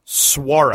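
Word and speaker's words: Szwarc: It's Jeno Swarak. – Oh, Jeno Swarak.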